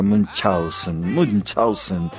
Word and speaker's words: Münchhausen: En realidad se pronuncia Münchhausen, que algún alemán me lo desmienta.